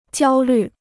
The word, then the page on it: 焦虑: jiāo lǜ